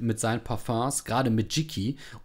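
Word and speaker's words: Jicky: Ähm, Aussprache übrigens Jiki.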